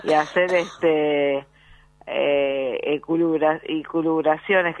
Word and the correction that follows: lucubraciones: que tampoco me sale la palabra, ¿cómo se dice, Barbie? – Escarola y lucubraciones.